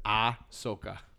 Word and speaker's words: Ahsoka: It's not Ahsoka. – That's how I told you how to pronounce it. Ahsoka.